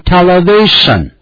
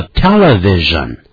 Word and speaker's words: television: Television 就指的是电视，可不要念成 television，叫 television，通常咱们缩写为 TV， T 是大写的， V 这个字母也是大写的。